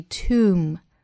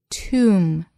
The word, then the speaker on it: tomb: 我们再来看美音。同样呢，清明节 Tomb Sweeping Day，要注意 tomb， tomb，末尾的 b 不发音，sweeping，